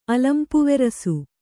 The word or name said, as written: ಅಲಂಪುವೆರಸು